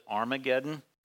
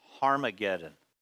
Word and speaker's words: Harmageddon: The Hebrew word here, and I know we pronounce it Armageddon, but it's Harmageddon.